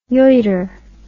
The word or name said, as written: yeutter